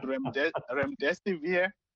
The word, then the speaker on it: Remdesivir: It's called Remdesivir.